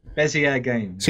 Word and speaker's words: Bezier: Bezier Games.